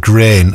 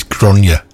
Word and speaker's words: Gráinne: Yeah, I pronounced it grain last week, because it's spelled G-R-A-I-N-N-E. Anyway, I got a text from Angie saying it's Gráinne.